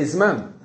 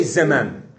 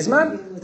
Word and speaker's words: zman: And yet all Israelis say zman. They don't say zeman. They say zman.